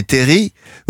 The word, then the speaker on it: terrils: On dit les terris ou les terrils? – Alors, le L ne se prononce pas exactement comme dans fusil.